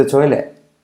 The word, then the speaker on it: toilet: La T de toilet última casi tampoco se dice.